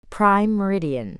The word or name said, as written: Prime Meridian